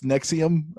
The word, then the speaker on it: NXIVM: N-X-I-V-M. And it's Nexium.